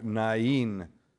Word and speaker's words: Nain: Today, we hear from the Gospel of St. Luke, the story of the widow of the city called Nain, or Nain, as it's pronounced in most other languages.